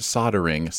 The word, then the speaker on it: soldering: And the comment is just soldering is pronounced soldering. Silent L, yo, you get a sin point.